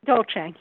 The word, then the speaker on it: Dolce: Merrill also has a friend, Dolce, is that how you pronounce it? – Dolce, yes.